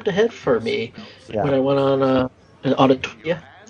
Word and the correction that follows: Autopia: Is it Autotopia? – Autopia. – Disneyland. Autopia, I'm putting in another syllable, but okay.